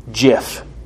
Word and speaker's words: GIF: And the creator, Steve Wilhite, told us it's pronounced GIF. It's pronounced GIF. Sorry.